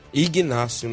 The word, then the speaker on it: Ignácio: Os gols foram marcados pelo Inácio e pelo Raí. Ignácio, né? Igna... É, mas é, esse G aí é, é mudo.